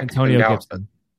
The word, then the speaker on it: Antonio Gibson: So, I know a lot of people love Aaron Gibson, and I think he's good. I would have considered taking a back. – Antonio Gibson. – Antonio Gibson. I apologize.